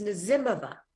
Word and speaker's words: Nazimova: It's a Russian name, and it's Nazimova.